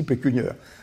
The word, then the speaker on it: pécuniaire: Puis un beau jour, je comprends que ça se dit pécuniaire.